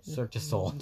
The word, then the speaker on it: Cirque du Soleil: Cirque de Los A? – Cirque du Soleil.